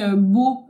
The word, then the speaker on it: Beau: Ça, moi, déjà, juste mon nom de famille, Beau, qui s'écrit B-E-A-U, pour les Espagnols, ça a été un calvaire toute leur vie d'essayer de prononcer mon nom de famille.